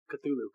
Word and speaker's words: Cthulhu: Cthulhu.